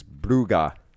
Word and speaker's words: Brugge: Which, by the way, it is Brugge.